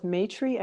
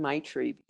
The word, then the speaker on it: Maitri: People pronounce it both Maitri and Maitri because of the way it's spelled, Maitri. So I've heard both. I'm no expert in phonetics, especially Sanskrit phonetics. So right. I'm going to say, you're right.